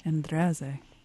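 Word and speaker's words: Endrezze: I hope I'm pronouncing her name correctly. – That's very close. – Okay. What is the correct pronunciation? – Andreze. – Andreze.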